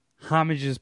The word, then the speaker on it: homage: I feel like they've had recent Super Bowl or halftime performances where there was like homages paid. Like last year, it was... did I say that wrong? Help me. – Homage, whatever.